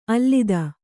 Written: ಅಲತೆ

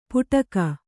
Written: ಪುಟಕ

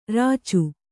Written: ರಚ್ಚೆ

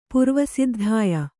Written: ಪುರ್ವಸಿದ್ಧಾಯ

